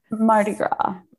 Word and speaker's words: Mardi Gras: Mardi Gras? – Mardi Gras.